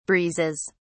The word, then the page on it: breezes: British English